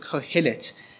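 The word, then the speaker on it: Qohelet: And this word in the Hebrew is the word Kohelet. Kohelet. Now, I want you to be familiar with that word because I'm going to refer to that word as we go through this series. He is called Kohelet. So it's, for those of you who want to spell it, so it's Q-O-H-E-L-E-T. Q-O-H-E-L-E-T.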